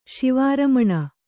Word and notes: ಶಿವಾರಮಣ: Kannada

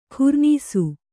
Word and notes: ಖುರ್ನಾಸ: Kannada

